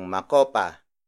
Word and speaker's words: makopa: For example, pitong makopa.